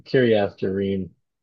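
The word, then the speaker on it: Kiriath Jearim: Kiryath Jearim. There's no J sound really, but that's how it's written.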